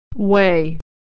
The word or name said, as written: weigh